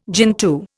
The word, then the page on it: gentoo: Indian